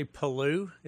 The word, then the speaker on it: Palou: So did you say Palou? Is that how you pronounce it? – Yeah, it's a lime green. It's spelled P-A-L-O-U.